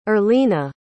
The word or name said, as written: earlina